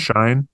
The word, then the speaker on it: Shein: If you've seen how my last name is spelled, S-H-E-I-N, it's pronounced Shine. You know, everyone thinks it's Sheen.